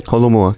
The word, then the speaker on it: Holomua: And one of the things that you had done this Holomua, am I pronouncing it right? – Holomua, correct.